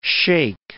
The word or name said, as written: shake